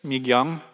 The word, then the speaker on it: Mi-kyung: Her name is Mi-kyung, and the K, it's M-I-K-Y-U-N-G, but the K, as I explained to Michael, is kind of a combination of a K and a G when you pronounce it.